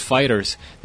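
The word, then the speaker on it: FighterZ: Mira, aquí ya está el tweet de Bandai Namco, la cuenta del Reino Unido, que dice: "We confirm that officially we pronounce it like the words fighters, not fighter C, not fighter Z, not fighter Zero, not fighters, just fighters."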